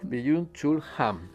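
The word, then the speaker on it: Byung-Chul Han: Byung Chul Han.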